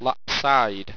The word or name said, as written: lapseyed